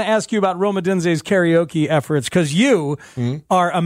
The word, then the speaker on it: karaoke: Say it right. – Karaoke. – Karaoke. God, mm-hmm. It just flows. It flows. – It's a soft roll of the R.